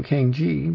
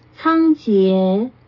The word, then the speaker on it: Cang Jie: And compare her name with the person I've been calling Kang Ji, but it's pronounced in Chinese. – Cang Jie.